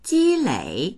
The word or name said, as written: ji1lei3